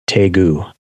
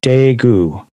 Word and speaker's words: Daegu: The name of the airport is Daegu. – Oh, it's the D is pronounced like a T. – It's yeah. Daegu. It's a soft T. – It's a soft Daegu.